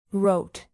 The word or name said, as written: rote